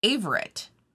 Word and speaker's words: Averett: In 1929, the founder Edwin, and at first I thought it was Avery, but Avery? I think it's just Averett. – Oh, but so spelled like Avery with the T at the end.